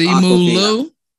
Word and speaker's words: Simu Liu: It's pronounced Simu Liu. – Simu Liu? – That's right.